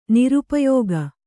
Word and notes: ನಿರುಪಯೋಗಿ: nirupayōgi

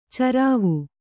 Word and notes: ಚರಾವು: carāvu

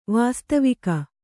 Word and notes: ವಾಸ್ತವಿಕ: vāstavika

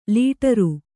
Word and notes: ಲಾಟರಿ: lāṭari

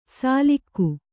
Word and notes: ಸಾಲಿಕ್ಕು: sālikku

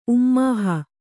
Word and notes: ಉಮ್ಮೋಹ: Kannada

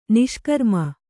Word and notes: ನಿಷ್ಕ್ರಾಮ: niṣkrāma

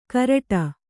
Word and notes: ಘರಟ್ಟ: gharaṭṭa

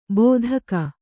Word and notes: ಬೋಧಕ: bōdhaka

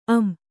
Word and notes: ಅಂ: Kannada

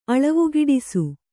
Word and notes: ಅಳವಿಗಿಡಿಸು: aḷavigiḍisu

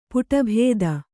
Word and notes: ಪುಟಭೇದ: Kannada

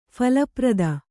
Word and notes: ಫಲಪ್ರದ: phala prada

